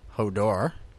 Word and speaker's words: Hodor: This next question is, how do you say Hodor? So is it Hodor, like a question? Just have a sip of wine instead. Hodor, normally. Hodor, kind of like in italics. Hodor, H-O-E, door, with a question mark. Hodor, so H-O-D-O-R.